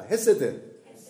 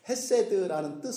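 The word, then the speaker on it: 헤세드 (חסד): 헤세드. 네, 아주 사랑스러운 단어입니다. 그리고 진리는 에메트라고 하는데 조금 설명을 하겠습니다. 헤세드라는 뜻은 무슨 뜻이냐면은 여러 가지 뜻이 있는데 그 중에 하나가 상전이 하인에게 베풀어 주는 친절, 배려.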